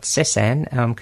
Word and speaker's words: Sesan: And forgot to mention, we had a text come in a little while ago from, I hope I have the pronunciation right, Sesan. Congratulations to Ireland for approving gay marriage. Yep, spot on.